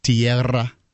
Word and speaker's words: Tierra: Would you like to roll your R's for this one? – Do you always have to roll your R's with? – It's double R. – Yeah. – Tierra. Okay. – Tierra.